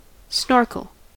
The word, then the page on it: snorkel: American English